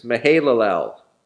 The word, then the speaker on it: Mahalalel: It's Mahalalel. You can spell it just like it sounds. Yeah, I didn't think that'd be good enough for some of you. All right. Mahalalel is M-A-H-A-L-A-L-E-L.